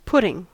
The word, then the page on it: putting: American English